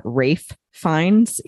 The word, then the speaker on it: Ralph Fiennes: Um, you know, and and especially knowing that it was starring, I think you pronounce his name Rafe Fiennes, even though it's spelled Ralph.